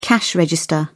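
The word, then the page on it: cashew(s): British English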